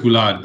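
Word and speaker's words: Bullard: It's pronounced Boulard, but it's B-U-L-L-A-R-D, Eugene Boulard.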